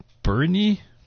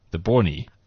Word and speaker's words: Boerne: It's called the Bernie? – There we go. – You're going to hurt yourself. Okay. – Guys, come on. B-O-E-R-N-E. How would you pronounce that? – B-O-E-R-N-E. – R. – The Bornie. – Okay. R-N-E.